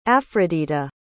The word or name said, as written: afrodita